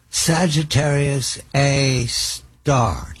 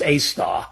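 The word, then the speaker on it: Sagittarius A*: Sagittarius A star. Did I say it correctly? – Yes, that's correct. It's A with an asterisk, but it's pronounced A star.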